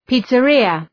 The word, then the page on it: pizzeria: ‘pi:tsə,rıə